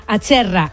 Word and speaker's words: Acerra: A ver si lo digo bien, ¿es Acerra o Acerra? – Acerra.